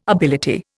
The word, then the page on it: ability: Indian